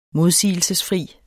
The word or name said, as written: modsigelsesfri